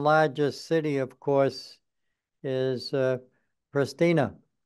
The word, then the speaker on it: Pristina: The capital of Kosovo is pronounced Pristina, and Kosovo is a new country which declared the independence from Serbia in 2008.